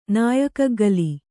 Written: ನಾಯಕಗ್ಗಲಿ

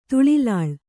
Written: ತುಳಿಲಾಳ್

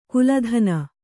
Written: ಕುಲಧನ

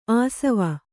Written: ಆಶ್ವ

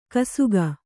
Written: ಗೋಸಗೆ

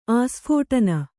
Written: ಆಸ್ಫೋಟನ

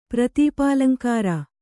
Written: ಪ್ರತೀಪಾಲಂಕಾರ

